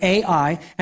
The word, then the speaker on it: Ai: The city's called, it's spelled Ai. It's probably said I, but Ai.